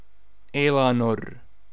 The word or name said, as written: Elanor